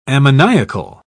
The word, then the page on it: ammoniacal: British English